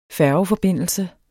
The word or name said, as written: færgeforbindelse